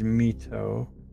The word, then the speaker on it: Mito: And so, oh yeah, one thing I wanted to say, because people probably don't know this, but my name is Mito. It's spelled M-I-T-O.